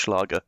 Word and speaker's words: Schlager: Now, my pronunciation may be terrible, but I believe it's pronounced Schlager.